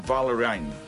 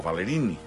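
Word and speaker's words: Ballerini: Kelsey Valerine, o Valerini, com també es pronuncia, depèn de l'ascendència que tinguis.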